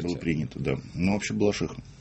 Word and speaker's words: Балашиха: Где ударение? Балашиха? – Балаших. – Балашиха. – Балаших. Только Балашиха.